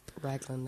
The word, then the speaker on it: Raglan: So there's no D in that name. – Raglan, no.